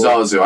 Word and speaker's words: Zazu: Zazu, I think you're right.